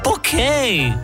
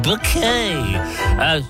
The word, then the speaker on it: Bucket: It's bouquet. It's pronounced bouquet.